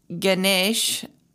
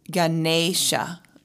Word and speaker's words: Ganesha: So this week's mantra is Ganesha Sharanam, and Ganesh is, it's pronounced, for a long time I was pronouncing it Ganesh, like an eh sound, but the E in Sanskrit, I believe, is pronounced like A, Ganesha.